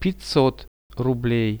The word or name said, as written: 500 rublei